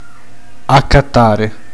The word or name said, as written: comprare